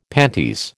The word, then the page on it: panties: American English